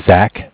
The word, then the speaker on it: Zach: So my name is Zach, spelled Z-A-C-H.